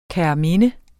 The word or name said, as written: kærminde